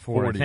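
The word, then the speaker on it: Forde: Mm-hmm, Pat Forty. I think. – I think it's 40. Isn't it pronounced 40? – It's F-O-R-D-E.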